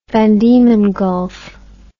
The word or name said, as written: Van Diemen Gulf